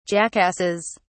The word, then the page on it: jackasses: British English